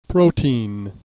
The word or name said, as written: protein